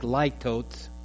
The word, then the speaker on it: litotes: And he explained to the class that there's a form of speech known as something that is spelled L-I-T-O-T-E-S. Now, it may look like litotes, but it's pronounced litotes.